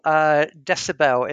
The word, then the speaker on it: dcbel: First of all, Decibel, is that the right pronunciation? – It is the right pronunciation. – Okay. And spelled D-C-B-E-L.